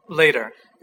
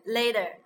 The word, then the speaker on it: later: Later. – Later. Okay. Later. It's not later. It's later because this T is not stressed. When T is stressed, they still pronounce as T, like teacher, right? – That's not teacher。嗯。